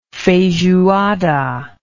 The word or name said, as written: feijoada